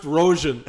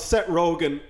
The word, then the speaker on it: Rogen: That was a callback to a different episode where Derek McGarrigle, the filmmaker, called Seth Rogen, Seth Rojan, like a fucking person who doesn't know how to spell Rojan. – I love how you're like, and you're a filmmaker, and you don't know how to pronounce Seth Rogen, like he's Laurence Olivier, like he's one of the great actors.